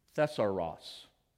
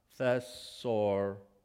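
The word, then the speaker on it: Thesauros (θησαυρός): Let me pronounce it for you in the Greek. Thesauros. Slow it down. Thesauros. That's where we get the word thesaurus.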